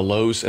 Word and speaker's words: Louhs: Friends, we're on Facebook, Twitter, Instagram, and YouTube, all under the headings of The Lows. And that last name is quite unique. It's spelled L-O-U-H-S. It's The Lows, and that's all of our platforms are under that heading.